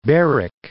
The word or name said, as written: Berwick